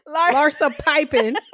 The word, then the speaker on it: Larsa Pippen: Look, speaking of sex and not having had a lot of sex, Larsa Pimpin. Oh, excuse me. Larsa right. Pimpin. – You said it right. Larsa Pipin.